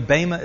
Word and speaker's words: Bema: By the way, Bema is spelled B-E-M-A. It's a long E.